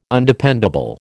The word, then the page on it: Undependable: American English